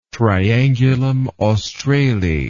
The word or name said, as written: triangulum australe